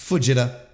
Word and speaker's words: Fujita: I got to get that pronunciation down better. Fujita. I have to stop pretending that it's Fujita. It's Fujita.